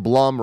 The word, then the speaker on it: Blum: And then, okay, so Gresick writes in and says, for Blumhouse, the Blum rhymes with plum, and I need to remember that.